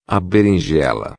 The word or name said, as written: berinjela